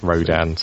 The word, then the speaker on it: RODIN: Which is R-O-D-I-N, which is pronounced Rodan to after mimic.